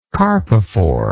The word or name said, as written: carpophore